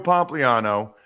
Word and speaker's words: Pompliano: His name is Joe Pompliano, at Joe, P-O-M-P-L-I-A-N-O. Am I pronouncing that right, by the way, Joe? I have no idea. – You're spot on, man.